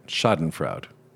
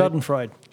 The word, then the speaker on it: Schadenfreude: As fate buffeted her to and fro, she incited in readers successive waves of envy, censoriousness, schadenfreude. I can't say it. Schadenfreude. Did I say it right? – Schadenfreude.